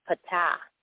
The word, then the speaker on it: Ptah: I was taught that the pronunciation is Ptah, P-T-A-H, Ptah.